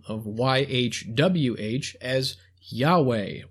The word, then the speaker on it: Yahweh: This name is pronounced Yahweh.